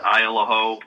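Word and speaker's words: Isle au Haut: It's spelled ISLE space AU space HAUT, but they pronounce it Isle of Ho, up in mid-coast Maine.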